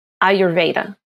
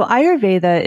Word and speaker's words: Ayurveda: And for our audience, can you tell us what is Ayurveda? And correct me if I pronounced mm-hmm. it wrong. – No, you said it exactly right. So Ayurveda is the healing tradition that originated in India.